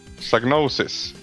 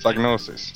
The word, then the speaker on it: Psygnosis: Psygnosis. – Jag har alltid sagt Psygnosis. – Okej. – Okay. Men jag har hört britter och amerikaner säga, ja, men som du sa, Psygnosis. – Psygnosis.